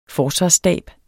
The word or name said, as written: forsvarsstab